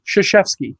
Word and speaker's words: Krzyzewski: He is pronounced Mike Krzyzewski. – Yeah, that no, that's not how it's spelled. – That's what I said. – That's how it's pronounced.